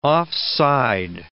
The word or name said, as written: offside